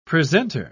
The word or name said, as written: presenter